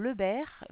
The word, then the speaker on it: Lebert: Alors, moi je suis Madame Lebert, ça s'écrit L-E-B-E-R, Sophie, et le service ça s'appelle Prof comme le début de professeur, oui.